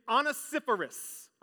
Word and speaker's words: Onesiphorus: It is Onesiphorus.